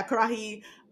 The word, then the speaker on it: Karahi: Kara- no, karahay. I don't know how to pronounce it. My, I auto corrected. – I think Karahi. – I think it was, yeah, Karahi uh, samosa with tamarind chutney and yogurt sauce.